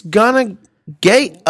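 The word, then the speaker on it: Gana Gueye: Correct. It's Ghana Gay.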